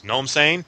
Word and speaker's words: Gnome Sane: Gnome Sane, and it's G-N-O-M-E, Gnome, Sane, S-A-N-E.